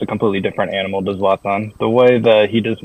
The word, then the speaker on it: Zlatan: Is that how you say his name, Zlatan? – Zlatan. Yeah. When Zlatan came, he was still...